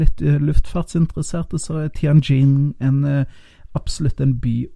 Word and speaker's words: Tianjin: Og den flyr mellom Oslo og Tianjin, eh, hvis det var riktig uttalt. Og det er jo Jeg tror det er ganske riktig uttaling eh, der, Thomas, faktisk. Tianjin. Tianjin, ja.